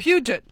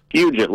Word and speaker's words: Puget: Puget. – Puget. – Puget, like a church pew.